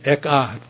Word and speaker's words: Echad: That Hebrew word for flesh is spelled E-C-H-A-D. Echad. Echad. E-C-H-A-D.